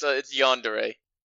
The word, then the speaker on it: yandere: Yeah, it's yandere.